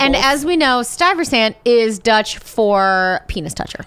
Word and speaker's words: Stuyvesant: And no one knows how to say it because his name looks like, yes, when you say it, it looks like Stuyvesant, mm-hmm, but it's Stuyvesant.